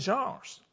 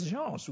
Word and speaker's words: genre: And so in religious writings in the Bible, there are also various genres. There are all the various genres. We know that. You see that I didn't call it genres. I say genre. That's the way it's pronounced.